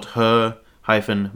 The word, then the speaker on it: Hermione: Rowling wrote into the novel a particular chapter where Victor Crumb was calling her name incorrectly by calling her Hermione, spelt her-my-own. Now, she was saying that on purpose out loud in the book, and a theory has always been, was that intentional to make us realize how to say the name correctly?